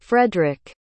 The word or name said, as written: frederic